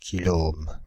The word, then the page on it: kiloohm: /ki.lɔ.om/; France (Île-de-France)